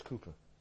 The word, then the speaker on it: Cowper: And then William Cooper, we sometimes pronounce his name Cowper, but it's Cooper.